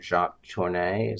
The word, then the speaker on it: Jacques Tourneur: If I may interject, I think a lot of that photography he was trying to emulate, like Jacques Tourneur, is that how you say his name? – Yeah.